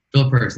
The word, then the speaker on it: Philip Hurst: Philip Hurst.